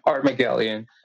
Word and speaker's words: Armagelion: So, I've been pronouncing it wrong for 3 months, so. – It's okay. Armagelion.